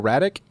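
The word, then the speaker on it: Radec: Colonel Raddick, is that how you pronounce it? – Yeah. Yeah.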